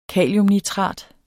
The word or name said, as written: kaliumnitrat